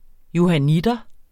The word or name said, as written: johanniter